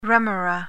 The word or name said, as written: remora